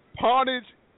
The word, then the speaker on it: pwnage: Pawnage, and it's spelled P-W-N-A-G-E, pawnage, is in dictionary now.